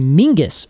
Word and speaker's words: Menzies: actual sound of Scottish menzies is actually, it's just there's no letter for it in the right. – English alphabet. So it's actually Mingus, apparently, is how you would pronounce menzies. If you were pronouncing it kind of the traditional Scottish way.